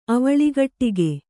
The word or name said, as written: ಅವಳಿಗಟ್ಟಿಗೆ